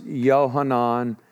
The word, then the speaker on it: Yohanan: We're still talking about John the Baptist, or here it's pronounced Yohanan or Jonathan. Once again, there's no J or J sound in Hebrew.